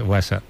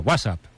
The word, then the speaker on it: WhatsApp: WhatsApp. No, peor aún, con acento en la primera, WhatsApp, ¿no? Un WhatsApp.